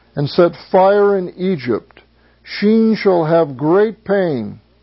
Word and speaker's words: Sin: I will pour my fury upon Sheen, as it is literally pronounced. You might have sin in your text, but it's actually Sheen.